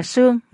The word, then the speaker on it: tương: Âm là tương, nhưng người già quen đọc là sương, nghĩa là cái rương, cái hòm.